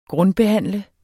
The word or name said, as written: grundbehandle